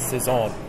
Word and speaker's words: Saison: But it's actually pronounced a Saison, according to the lady when I got it. Paradise Saison.